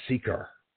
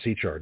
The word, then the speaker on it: Sychar: In verses 4 through 6, we read that Jesus and his disciples came to the town of Sychar. Or Sychar, depending on how you want to pronounce it, at the sixth hour of the day, at the site of Jacob's well.